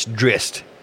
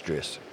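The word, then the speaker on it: Drizzt: It's Dritzed. I said it wrong for 20 years, but it's if you listen to other people, it's Dritzed.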